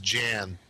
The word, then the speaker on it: Jann: And I believe it's pronounced Jan, J-A-N-N.